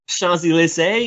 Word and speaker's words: Champs-Élysées: But more interesting, you said you know how to pronounce Champs Elysees correctly. – Champs-Élysées, you mean? – There you go. Ah, wow, it even sounded French when you said it.